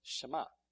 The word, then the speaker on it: Shema: The E is silent as they pronounce it, so it's Shema.